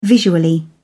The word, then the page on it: visually: British English